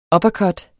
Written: uppercut

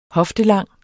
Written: hoftelang

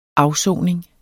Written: afsoning

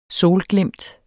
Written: solglimt

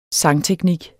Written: sangteknik

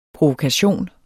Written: PROVOKATIONERS

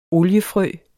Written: oliefrø

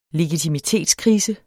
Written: legitimitetskrise

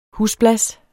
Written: husblas

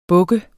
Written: buk

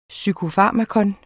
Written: PSYKOFARMAKA

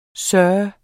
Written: SIRS

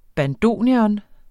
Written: bandoneon

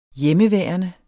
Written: hjemmeværende

